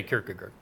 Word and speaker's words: Kierkegaard: that's the way you say Kierkegaard.